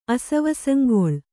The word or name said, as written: ಅಸವಸಂಗೊಳ್